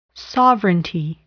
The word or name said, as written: sovereignty